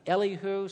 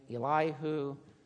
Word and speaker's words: Elihu: I'm going to call him Elihu. Some people pronounce his name Elihu. Choose. You can pronounce it either way.